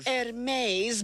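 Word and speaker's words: Hermès: Hermes Birkin bags for her first Mother's Day on Sunday.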